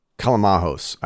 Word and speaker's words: Callimahos: Callimahos, I think is how you pronounce his name. – Yep.